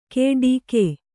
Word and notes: ಕೆಡಿಕೆ: keḍike

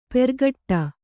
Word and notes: ಪೆರ್ಗಟ್ಟ: kannada

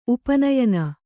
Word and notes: ಉಪನಯನ: upa nayana

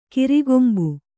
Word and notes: ಕೊರೆಕಂಬಿ: korekambi

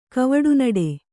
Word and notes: ಕವಡುನಡೆ: kavaḍunaḍe

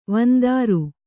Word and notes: ವಂದರಿ: vandari

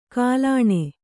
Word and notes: ಕಾಲಾಣೆ: kālāṇe

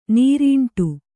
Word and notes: ನೀರಂಡೆ: kannada

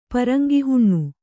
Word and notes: ಪರಂಗಿಹುಣ್ಣು: Kannada

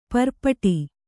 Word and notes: ಪರ್ಪಟಿ: kannada

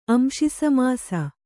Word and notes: ಅಂಶಿಸಮಾಸ: kannada

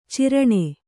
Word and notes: ಚೀರಣೆ: cīraṇe

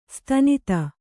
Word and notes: ಸ್ತನಿತ: stanita

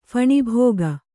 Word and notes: ಫಣಿಭೋಗ: Kannada

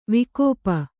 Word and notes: ವಿಕೋಪ: vikōpa; Kannada

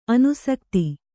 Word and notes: ಅನುಸಕ್ತಿ: kannada; anusakti

